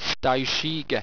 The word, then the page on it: styshege: owstwecian